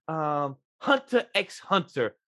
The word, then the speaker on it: Hunter x Hunter: Hunter x Hunter, or people don't pronounce the X, so I'm sorry.